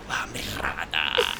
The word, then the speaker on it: La Mirada: The address is at 5303 La Mirada, or La Mirada. If you like to roll your R's.